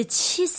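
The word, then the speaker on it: qi (气): Le qi.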